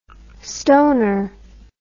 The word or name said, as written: stoner